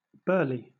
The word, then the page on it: burley: British English